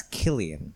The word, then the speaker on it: Cillian: I believe it's Killian. – I think okay. It's Cillian. – I think it's been Killian this whole time and we've all been mispronouncing it.